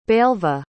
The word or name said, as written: belva